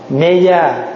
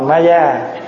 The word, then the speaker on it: Ma Gia: Ma Gia là mình phát âm theo tiếng Phạn nghe, Mê Gia, nhưng mà mình đọc là Ma Gia, chứ không phải nó trời mẹ Phật tên xấu quá.